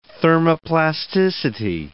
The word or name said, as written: thermoplasticity